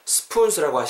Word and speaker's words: spoons: spoons 발음하시도 spoons라고 하시기보다는 spoons. 약간 쌍비읍으로 발음하시고 spoons 길게 발음해 주시면 진짜 기가 막힐 것 같아요.